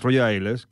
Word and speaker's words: Roger Ailes: Eh, básicamente porque a veces suena este nombre, George, George eh, eh, bueno, sería Roger, Roger Rolls, no sé cómo se pronunciaría la verdad, la verdad. Es Roger Ailes, que hizo famosas las fake news.